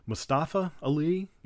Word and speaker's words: Mustafa Ali: But is it Mustafa Ali? Yeah.